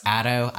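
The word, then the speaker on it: Ato: It's actually pronounced Otto. I've been pronouncing my character's name wrong. It's not Otto. It's A-T-O, not A-U-T-O.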